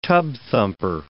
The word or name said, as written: tub-thumper